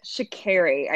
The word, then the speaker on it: Sha'Carri: Sha'Carri. I did watch a video today and she pronounced it herself. So it is truly Sha'Carri Richardson, which I was getting it wrong.